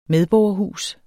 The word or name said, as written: medborgerhus